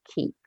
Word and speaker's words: Keap: And it's spelled K-E-A-P, KEEP.